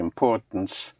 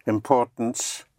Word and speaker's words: importance: Importance. – Importance. – Importance. The IM and IN importance. Is un.